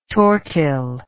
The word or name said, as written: tortile